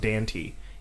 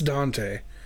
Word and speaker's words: Dante: But if I understand correctly, this was the command or just like the jeering that Pitch gave to the dog Dante. He says, bite him. – It's Dante. Don't use his stupid pronunciation.